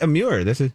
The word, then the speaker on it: Amur: And you said it right, Amur.